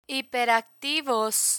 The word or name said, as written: hiperactivos